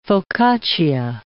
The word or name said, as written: focaccia